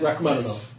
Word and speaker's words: Rachmaninoff: And you still got it wrong. – It is Russian or something. – He is. Rachmaninoff. – Rachmaninoff. – There you go.